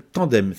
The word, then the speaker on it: Tan2em: Le groupe qui interprétait cette chanson, ça s'appelle Tandem. Ça s'écrit T-A-N-2-E-M. Oui, c'est comme ça.